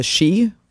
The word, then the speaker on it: sidhe: But the fairy race, they refer to them as the she, and it's spelled S-I-D-H-E. But the she or spirit race,